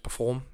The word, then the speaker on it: Perfform: And here we are, perform, two Fs. So that second F is very important, so don't forget that. You don't have to pronounce this, you know, it's a silent F, it's just perform.